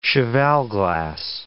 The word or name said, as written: cheval glass